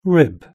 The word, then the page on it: rib: British English